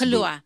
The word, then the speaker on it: Kaluuya: Like, people were outraged that when her and what's his name from Get Out, Daniel Kaluuya. – Oh my gosh. – Is that how you say his name? – Yeah, Daniel Kaluuya, my wife's Kaluuya.